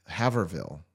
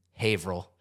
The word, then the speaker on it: Haverhill: Haverville. – Haverville. – Haverville? – Haverhill.